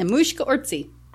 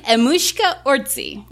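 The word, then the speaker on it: Emmuska Orczy: Mm-hmm, Emushka Orczy. – Say that again. – Emushka Orczy.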